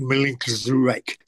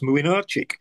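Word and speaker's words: Młynarczyk: And the goalkeeper of Poland was spelt M-L-Y-N-C-Z-A-R-E-K. Looks like Milinkzurek. So I went to check and it's pronounced Mlynarczyk.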